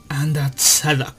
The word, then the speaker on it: Under the Dog: Una ova que al fin me decidí a ver llamada Under the Duck. Bueno, eh, eh, en sí se pronunciaría como Under the Dog.